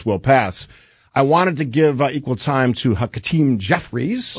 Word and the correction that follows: Hakeem: Hakeem. – There you go. – What did I say? Hakeem. – You added a syllable.